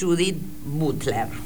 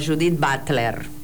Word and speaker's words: Judith Butler: que elegí, que es Judith Butler, o Judith Butler, depende de dónde naciste y cómo querés pronunciar.